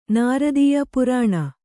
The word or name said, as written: ನೃತ್ಯ